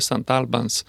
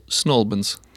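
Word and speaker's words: St Albans: No, gli italiani tradizionalmente pronunciavano sempre St. Albans. Però oggi la gente di lì dicono soltanto Snowbans. – Attenzione, Snowbans, due sillabe e basta.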